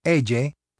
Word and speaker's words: ella: ella is pronounced like the le sound in million, except that it is one single sound.